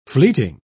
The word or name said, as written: fleeting